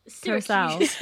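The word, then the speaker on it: Curaçao: Basically, so I ordered a like pineapple and coconut blue Sirocco, how the flip you say it, blue Curacao. How do you say it? – Curacao. – It's like Curacao.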